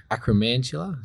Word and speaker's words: Acromantula: Charlton Eddie leading with Acromantula. Is that the right pronunciation? – Yep.